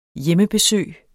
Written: hjemmebesøg

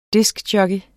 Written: diskjockey

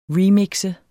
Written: remix

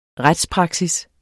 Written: retspraksis

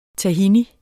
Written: tahin

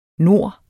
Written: nor

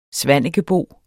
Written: svanekebo

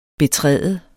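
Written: BETRÆD

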